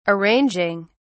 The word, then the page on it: arranging: British English